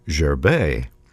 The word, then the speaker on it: Gerber: I did find a composer by the name of Gerber, G-E-R-B-E-R, although he's a Swiss composer, died in 2006, and his name is pronounced René Gerbet.